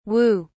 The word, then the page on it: whoo: British English